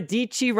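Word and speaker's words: Dietschy: Let's go ahead and jump over to my screen real quick here, where I've got the one and only Sarah Dicci rhymes with Peachy announcing Lab 22.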